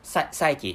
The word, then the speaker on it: psyche: So you know, I mistakenly pronounced psych, but actually, so the correct pronunciation is psyche, not psych.